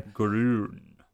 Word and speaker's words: Groen: Groen.